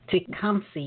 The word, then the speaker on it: Tecumseh: Tecumseh, you said. – Yeah. It's named after an Indian. – Okay. I pronounced it correctly. Good. – Yes, you did.